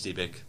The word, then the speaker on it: Dybbuk: It is Dybbuk.